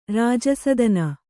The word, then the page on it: ರಾಜಾಸ್ಥಾನ: kannada